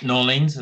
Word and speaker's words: New Orleans: Um, but I have been to uh, New Orleans or or New Orleans, as I should probably pronounce it, as they would over there.